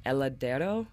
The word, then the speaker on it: Heladero: Heladero.